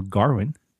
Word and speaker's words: Gawain: The film stars Dev Patel as Gawain, or Garwin, as they like to pronounce in the film.